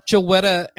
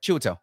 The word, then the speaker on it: Chiwetel: Chiwetel.